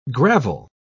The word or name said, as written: gravel